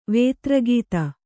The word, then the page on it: ವೇತ್ರಗೀತ: vētra gīta